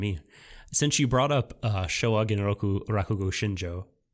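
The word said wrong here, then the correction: Rakugo Shinjū: I totally probably mispronounced it. Rakugo Shinju.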